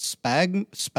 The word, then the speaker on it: Sphagnum: So do you want to touch on, and I'm going to mispronounce this, what is it, Sphagnum. – Sphagnum. – Yes.